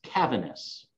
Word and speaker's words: Cavaness: It's Cavaness.